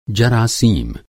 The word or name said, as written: जरासीम